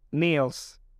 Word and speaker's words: Niels: Mi nombre es Daniel, aunque me suelen llamar Niels. Sí, o sea, N-I-E-L-S. ¿Cómo se dice? Niels, Niles, no sé.